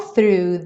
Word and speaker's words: through: All through the night. Through. Exatamente a mesma pronúncia do thriller, esse começo, né? Do th-th-th puxando no R.